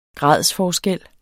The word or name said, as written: gradsforskel